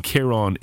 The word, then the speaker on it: Kieron: Pronunciation, for example, Rian is pronounced R-E-E-A-N. – Oh yeah, makes sense, yeah. – And then Kieron is K-E-E-R-O-N.